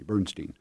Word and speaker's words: Bernstein: But in the trio, Peter Bernstein on guitar, I asked him about pronunciation and he corrected me, Bernstein, and Bill Stewart on drums, recorded in the year 2000.